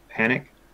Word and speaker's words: Panuke: Panuk Lake. – Yep. – Yeah, it's spelled P-A-N-U-K-E, but I think they call it Panic. It is the local, let's call it.